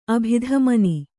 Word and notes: ಅಭಿಧಮನಿ: kannada

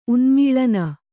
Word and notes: ಉನ್ಮೂಲನ: unmūlana

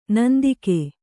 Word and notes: ನಂದಿಕ: nandika

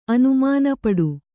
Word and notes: ಅನುಮಾನಪಡು: anumānapaḍu

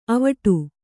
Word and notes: ಅವಟ: avaṭa

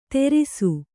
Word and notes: ತೆರಿಸು: Kannada

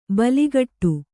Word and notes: ಬೆಲೆಗುಡು: beleguḍu

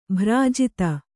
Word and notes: ಭ್ರಾಜಿತ: bhrājita; kannada

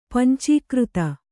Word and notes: ಪಂಚೀಕೃತಿ: pancīkřti

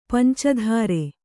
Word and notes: ಪಂಚಧಾರೆ: panca dhāre

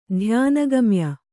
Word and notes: ಧ್ಯಾನಗಮ್ಯೆ: dhyāna gamye

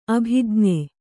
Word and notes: ಅಭಿಜ್ಞೆ: abhijne

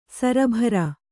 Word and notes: ಸರಬರ: sarabara

